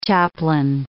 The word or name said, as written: chaplain